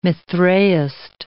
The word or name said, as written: Mithraist